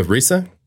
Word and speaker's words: RZA: Ya aquí es ya cuando The Genius se hizo The Giza, que se escribe G-Z-A. Simón. Pero se pronuncia Giza. Giza. Prince Rakim es The Risa, que es R-Z-A.